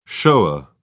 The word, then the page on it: shower: British English